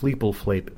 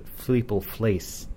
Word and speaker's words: Fleepleflase: So it wasn't Fleepleflape, Fleepleflase. And that's always been the pronunciation. It's not Fleepleflase, it's Fleepleflase.